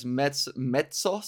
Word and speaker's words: mezzo: So he, and then, I don't know if you care, but there's mezzo soprano or mezzo soprano. Mezzo, I'm going to say it like Italian.